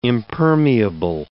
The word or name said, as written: impermeable